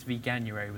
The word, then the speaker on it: Veganuary: I mean, January, I always pronounce it wrong, but it's Veganuary was a big kind of thing in January.